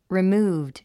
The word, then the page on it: removed: rimúːvd; リィムーブド